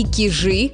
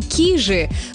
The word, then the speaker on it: Кижи: А вы знаете, что знаменитый музей-заповедник деревянного зодчества можно называть и Кижи, как все привыкли, и Кижи? Более того, долгое время правильным словарным был только вариант с ударением на первый слог.